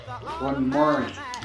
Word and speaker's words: Glenmorangie: Glenmorangie. – Oh, look at me. Got to brush up on my Francais or whatever that... What is it, French? – Scottish. Mm. It rhymes with orange.